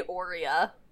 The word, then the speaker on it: Aurea: I say Aurea. Just like I would say Oreo, but with an A.